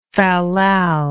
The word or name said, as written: fallal